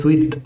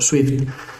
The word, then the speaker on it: Zwift: Si esto de la pronunciación no te queda muy claro, te lo deletreo: Z-W-I-F-T, Swift o Swift.